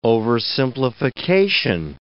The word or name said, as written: oversimplification